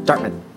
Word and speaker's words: Dartmouth: Dartmouth.